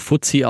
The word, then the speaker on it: FTSE: Das ist der FTSE, spricht man auch Fuzzi aus, FTSE All World.